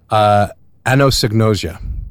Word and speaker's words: Anosognosia: Anosognosia, which means...